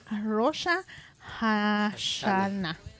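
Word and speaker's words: Rosh Hashaná: Rosha Hashana.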